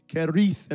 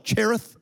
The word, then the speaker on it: Cherith: I've heard people pronounce Cherith all kinds of ways. They try to roll it and Kerith and all. We're just going to call it Cherith.